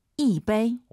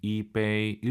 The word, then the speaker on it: 一杯: 一杯. – 오, 청취자분들 지금 이杯 일성으로 하시는 분 계시네요. 숫자 1 다음에 일성이 오면은 숫자 1이 사성으로 바뀝니다. 이杯 이렇게 발음하세요.